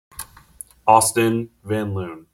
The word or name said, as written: Austin Van Loon